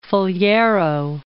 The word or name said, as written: Faliero